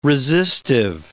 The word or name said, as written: resistive